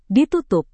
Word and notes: ditutup: Indonesian

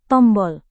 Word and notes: tombol: Indonesian

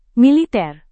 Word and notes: militer: Indonesian

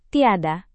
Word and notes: tiada: Indonesian

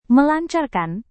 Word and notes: melancarkan: Indonesian

